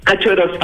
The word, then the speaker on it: Kaczorowski: Kazorowski. – Ah, Kazorowski, ya, porque se escribe K-A-C-Z-O-R-O-W-S-K-I. Eso es como entre ruso y...